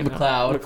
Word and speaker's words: McLeod: Did I pronounce his name right? – McLeod. – McLeod, BJ McLeod.